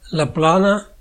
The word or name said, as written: Plaine